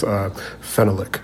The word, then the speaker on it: phenolic: If you write it out phonetically, it begins with an F, but it actually begins with a P. – Of course. – And I've written it out phonetically because phenolic just sounds...